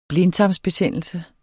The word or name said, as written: blindtarmsbetændelse